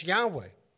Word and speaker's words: Yahweh: And if anybody would understand how to pronounce the name of the Mighty One of the Israelites, it would be Jewish scholarship. And based on the evidence, based on the Greek evidence, they are saying and confirming that that name is Yahweh.